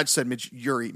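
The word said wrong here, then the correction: Midge Ure: Midge Yor, sorry.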